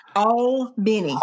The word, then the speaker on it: Albany: The official phonetic pronunciation is AWL-BENNY. Albany.